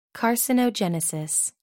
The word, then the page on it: carcinogenesis: American English